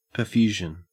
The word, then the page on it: perfusion: Australian English